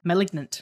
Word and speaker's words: Malignant: Did I just say it right? Malignant. Yeah. Sorry.